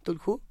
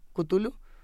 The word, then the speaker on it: Cthulhu: que no se puede pronunciar así, es como Tulhu, Cthulhu, Chuchuluf, Chichiluf, bueno, es el ser que no se puede nombrar de Lovecraft en Taxidermia de Colmillos y Garras.